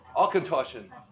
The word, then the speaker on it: Auchentoshan: And this one is pronounced Auchentoshan.